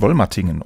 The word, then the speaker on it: Wollmatingen: Es war Januar 2012 in Wollmatingen. Habe ich das richtig ausgesprochen? – Ich glaube, es heißt Wollmatingen, oder? – Wollmatingen. – Wollmatingen. Okay.